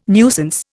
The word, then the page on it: nuisance: Indian